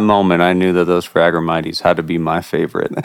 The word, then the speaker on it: Phragmites: If you read it how it's spelled, it says Phragmites. – Okay. – We plugged it into Google and clicked read. It said Phragmites. And I've heard it pronounced from a few experts calling it Phragmites. – Yeah. – So I think that's what we're going to go with.